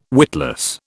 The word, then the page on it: witless: British English